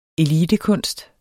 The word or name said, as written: elitekunst